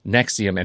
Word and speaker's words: NXIVM: But if you look for Allison Mack in Nexium, and it's spelled NXIVM,